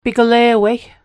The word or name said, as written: piglewei